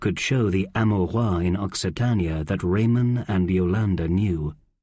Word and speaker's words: Occitania: That CC is pronounced X, making it Occitania.